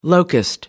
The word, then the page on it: locust: American English